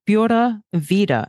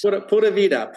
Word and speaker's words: Pura Vida: It's going Pura Vida and rolling it. So P-U-R-A-V-I-D as in David A. And we'll put it in our show notes. – Pura Vida. Pura Vida. – Going Pura Vida.